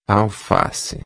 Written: alface

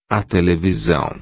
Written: televisão

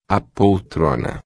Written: poltrona

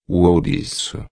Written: ourico

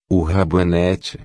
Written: rabanete

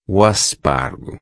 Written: aspargo

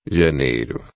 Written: janeiro

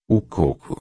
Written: coco

